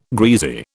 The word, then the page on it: greasy: British English